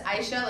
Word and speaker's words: Aisha: Yeah, my name is Aisha. I'm like, everyone pronounces it a little bit differently, I think, and it gets screwed up all the time. It's just Aisha.